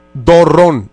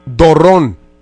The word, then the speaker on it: Dorrón: Dorrón. D-O-D-R-O-N con acento en la última O. – ¿Felipe qué, perdón? – Dorrón. Dorrón, sabes que soy extranjero, no soy de aquí.